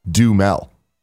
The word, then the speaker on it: Duhamel: It's actually just Duhamel. You just don't pronounce the A at all.